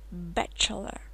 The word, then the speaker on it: bachelor: Bachelor, bachelor. Твердо читается, э открыто, как в кошке. Cat, bachelor.